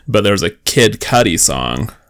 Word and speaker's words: Cudi: Cause so how it's spelled is Kid, but then C-U-D-I, Cudi. And she said, I didn't know that's how he spelled Kid Cutie.